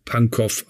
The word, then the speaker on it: Pankow: Keiner weiß eigentlich so richtig, Eva, warum man das nicht Pankow ausspricht. Denn in allen slawischen Sprachen wird dies off ausgesprochen. Ja, ich erinnere mich, dass ich mal in Moskau war da mal ein paar Monate äh, Beef Stroganov gegessen habe.